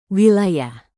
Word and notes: wilayah: indonesian

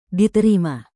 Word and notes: diterima: Indonesian

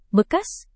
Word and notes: bekas: indonesian

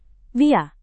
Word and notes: via: Indonesian

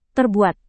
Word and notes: terbuat: indonesian

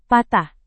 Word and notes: patah: Indonesian

